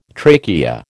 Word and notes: trachea: American English

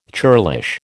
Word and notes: churlish: American English

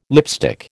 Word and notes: lipstick: American English

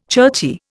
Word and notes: churchy: Indian

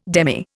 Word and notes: demy: Indian

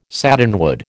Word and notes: satinwood: American English

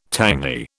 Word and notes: tangy: British English

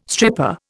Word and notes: stripper: Indian

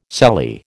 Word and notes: sallee: American English

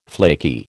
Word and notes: flaky: American English

